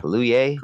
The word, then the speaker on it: Kaluuya: Kaluuya? – Yeah. – Kaluuya.